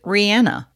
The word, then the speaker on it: Rihanna: There's even more examples of proper names that you would think would be simple, and yet most people call her Rihanna. She pronounces it Rihanna, although she has come out and said at this point, whatever.